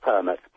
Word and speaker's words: Permutt: How did I do on your last name? – Not bad at all. It's Philip Permutt. – See, I was close. – You were right on, actually.